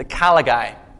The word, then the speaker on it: caligae: They were open-toed boots called the caligae. They sound like call a guy.